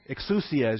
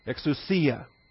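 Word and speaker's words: Exousia (ἐξουσία): This rite, exousia is your vocabulary. I gave it to you Wednesday night. Exousia. Put the accent there on that penultimate syllable.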